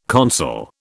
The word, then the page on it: consul: British English